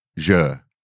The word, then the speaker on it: Niger: Niger is pronounced N-E-E, soft lowercase. – Google to tell us. – Hyphen, Niger.